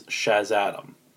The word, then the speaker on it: Shazadam: Basically, in that book, Black Adam introduces himself as Shaz-Adam. So it's Shazam and Adam combined into one. I'll spell it for you. S-H-A-Z-A-D-A-M. Shaz-Adam.